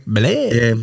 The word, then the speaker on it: Billet: It's no French for Billy. Billet.